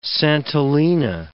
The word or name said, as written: santolina